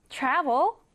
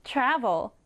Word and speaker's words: travel: travel, travel인데요. 여행하다 동사도 되고요, 명사로 여행도 됩니다. 읽을 때는요, 앞에 강세를 강하게 주세요. 읽어보면 travel, travel 이렇게 발음이 되겠죠?